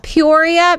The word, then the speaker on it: Peoria: You've got this Peoria Music Live.